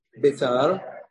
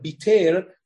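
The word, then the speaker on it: Beitar: Yerushalayim, Tur Malka, Haramelech was another big place, the Gemara says in Gittin, and another one was Biter, commonly mispronounced as Betar. That's wrong. The correct pronunciation, you see from the Geonim and Rishonim that made poems about Korban Bet HaMikdash, they always rhyme the city of Biter with words that have Chirik and Tzere. Not, it never rhymes with Be'er, it always rhymes with Ier, which means obviously they pronounced the name of the city as Biter.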